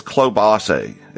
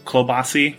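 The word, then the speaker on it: klobasy: What is klobase? How do you say that again? – So klobas. – Klobas. – Klobasi for the plural.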